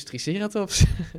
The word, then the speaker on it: Triceratops: Nou, dat is dus Triceratops. – Maar dat is dan de Engelse klemtoon. – Ja, dat is ook flauw van mij, want het mag eigenlijk allebei.